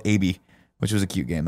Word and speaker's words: ABI: AB, like it's a robot. That's how the guy pronounced it.